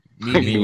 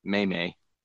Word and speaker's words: meme: Meme-y, like it's meme-like. – Meme-ish. – Yeah. – Meme-lish. – If I was trying to say it incorrectly, I would have said may-may. – Meme.